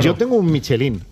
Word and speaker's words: Michelin: A ver, aquí en la Península Ibérica hacemos Michelin. – Sí, claro. Claro, pero la realidad, tú eres un chef internacional. – Ya, pero... – ¿Tú cómo lo pronuncias? – Me cuesta ser tan tontorraco, ¿no? Dice Michelin. – ¿Michelin? – Yo digo Michelin.